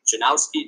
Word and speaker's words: Janowski: My name is Justin Janowski, just as it's spelled. Or it's spelled, just as it sounds.